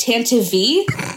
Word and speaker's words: Tantive: Tantine episode? Or Tantive. – It's actually pronounced Tantive.